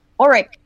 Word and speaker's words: Auric: Auric, like gold. – Auric.